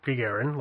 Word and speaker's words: Gagarin: Better than Yuri Gagarin.